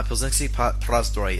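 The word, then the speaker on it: Plzeňský Prazdroj: Which is the Czech term for that, if I can actually say this correctly, is Pilsenský prazdroj. – Mm-hmm. – Pilsenský prazdroj is what you'll hear here in Czech Republic when you say you want a Pilsner Urquell.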